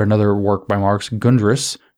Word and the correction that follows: Grundrisse: Grundrisse, I can't pronounce things in German, G-R-U-N-D-R-I-S-S-E, SSE, Grundrisse.